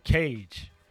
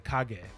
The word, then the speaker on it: Kage: One of the games I could say that's really closely related to is a game called Legend of Cage, or in the hood we saw, you playing Legend of Cage, because we didn't know how to pronounce it. So it's actually Legend of Kage, which is Shadow.